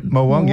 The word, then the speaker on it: Morwong: So he's got whole fish, mullet, silver bream, silver trelly, Trevelli, blackfish, tailor, yellowtail, blue mackerel, leather jackets, snapper, Morwong, have I said that right? – Yep, Morwong, yep.